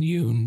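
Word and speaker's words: Yeun: So the spoiler section, I think, is Gordy and Steven Yuan. Did I say his name right? How do I say his name? – Oh, it's Steven Yuen, but...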